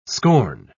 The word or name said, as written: scorn